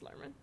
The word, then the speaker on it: Luhrmann: Luhrmann, Lumen, how do you say his name? – It's Luhrmann.